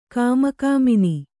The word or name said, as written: ಕಾಮಕಾಮಿನಿ